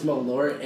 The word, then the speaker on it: Malort: It's like Merlot, and then but it's like pronounced weird. – Yeah, it's Malort, and it's like super boozy.